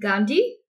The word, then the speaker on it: Gondi: So I'm very excited to be here with Charlene Gondi. Did I pronounce this right? – You did, yeah.